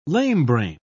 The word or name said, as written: lamebrain